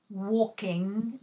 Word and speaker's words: walking: I like reading novels about history, walking, the L is not pronounced.